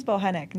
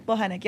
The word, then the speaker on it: Bochenek: Last name is Bochenek. Nobody ever says it right. – I was going to say like Bomachek. – Close enough. – So it's Bochenek? – Bochenek. Yep, the C is silent.